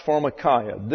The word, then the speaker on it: pharmakeia: And it's pronounced pharmakia. This is a diphthong here, and it sounds like a long English I.